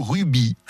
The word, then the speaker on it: rugby: Sidi Rabasta, Lou Ruby, sans prononcer le G.